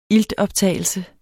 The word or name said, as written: iltoptagelse